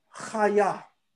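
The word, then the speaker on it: chaya (חַיָּה): The word for life in Hebrew is chaya. Chaya. That's how you say life.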